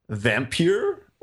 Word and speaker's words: Vampyr: So the game is called Vampyr or Vampire. It's spelled with like a P-Y-R situation.